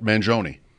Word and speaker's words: Mangione: My friend says that's how you say it, Mangione. I always thought it was Mangione. He's like, everybody says it wrong.